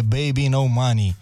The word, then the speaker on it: Baby No Money: Așa, dacă o cauți, caut-o așa, dar uh, se citește, numele lui e mm-hmm. Babe, sau mă rog, așa, așa se pronunță, e Baby No Money.